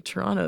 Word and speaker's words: Toronto: There were two prominent Toronto feminists. I pronounced the T in Toronto there. That's very un-Toronto of me.